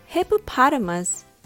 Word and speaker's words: hippopotamus: This word has five syllables. Let's divide it into the two sections. The first two syllables are pronounced hippo, hippo. So sometimes they are called hippos. The second part with three syllables are pronounced potamus, potamus. Put them together they have a rhythm that goes ta da ta da da, hippopotamus.